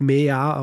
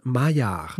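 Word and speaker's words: Maillard: die Reaktionen benannt sind, die beim äh, Anbraten von Fleisch auftreten, er spricht sich nicht Mea aus, sondern Majar.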